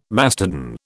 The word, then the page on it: mastodon: British English